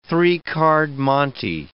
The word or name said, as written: three-card monte